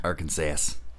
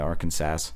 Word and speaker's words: Arkansas: Arkansas, then Mississippi, then Arkansas. – Yeah, Arkansas. – The S is silent.